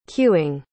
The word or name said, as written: queueing